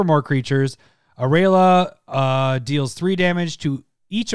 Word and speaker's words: Aurelia: My next one would be Aurelia. Is that her name? Is that how you say her name? – Yes, Aurelia.